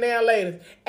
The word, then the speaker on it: Now and Later: Nobody says now and later. We all say now later. We put that right together.